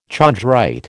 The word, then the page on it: chondrite: American English